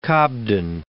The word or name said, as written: Cobden